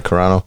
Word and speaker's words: Carano: This is my take from this whole Gina Carano... That's how you say her name, right? Carano? – Yep.